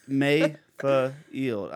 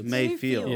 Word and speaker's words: Mayfield: I'm your host, Ben Mayfield. I don't know why I did that. I don't know why I made it three syllables. Yeah. But Mayfield. I don't think no, this is Mayfield.